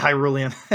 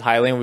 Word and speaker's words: Hylian: Did you always pronounce it Hylian when you were a kid? – Hylian? – Yeah. – Yeah, I had to think so. Oh no, that's not true. No. I always said Hyrulean.